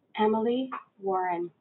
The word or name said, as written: Warren Emily